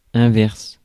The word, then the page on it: converse: /kənˈvɝs/; /kənˈvɜːs/; /ˈkɒnvɜːs/; /ˈkɑnvɝs/; /kənˈvɛːs/